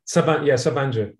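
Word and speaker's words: Sabancı: And so the one that you mentioned at the, how do you say it again? Sabanci? – Sabanci. Yeah, exactly.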